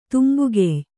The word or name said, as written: ತುಂಬುಗೆಯ್